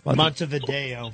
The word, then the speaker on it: Montevideo: Is it Montevideo? How do you say it? – Montevideo. – Yeah, okay, great.